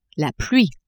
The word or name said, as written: pluie